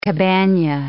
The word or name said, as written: cabana